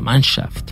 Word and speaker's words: Mannschaft: Sí, se pronuncia así, la Mannschaft.